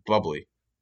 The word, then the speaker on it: Bublé: And I don't like Michael actually, Bublé. It's pronounced bubbly.